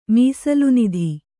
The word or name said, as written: ಮೀಸಲುನಿಧಿ